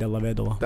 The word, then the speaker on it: Dellavedova: That's what But they call him Dellavedova. It's actually pronounced in Italian, Dellavedova.